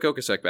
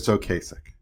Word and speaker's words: Ocasek: We need a Rick Ocasek back again, you know? – We do. – Am I pronouncing that right? It's Ocasek. – Ocasek, thank you.